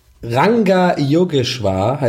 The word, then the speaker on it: Ranga Yogeshwar: Wie heißt der? Wie spricht man das? Yoga Rangeshwar. – Ranga Yogeshwar heißt der.